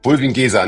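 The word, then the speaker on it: Bulwiengesa: Bullwien Geser, nicht Bullwien und ja, Geser.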